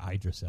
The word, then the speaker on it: Idris: I've heard it pronounced both ways, but I'm going Idris because that looks like a short eye to me.